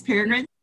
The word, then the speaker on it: Peregrine: So Miss Peregrine, is it Miss Peregrine? – Yeah.